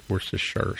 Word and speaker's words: Worcestershire: He decided to email us because, you know, I don't know, but he pointed out that Worcestershire, which is where Worcestershire sauce is from, in the UK is pronounced Worcestershire sauce, which is how that video we talked about did it.